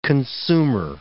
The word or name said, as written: consumer